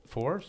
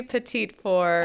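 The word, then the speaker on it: petit fours: Well, French would be petite four, ah. – but we just yeah, pedophore.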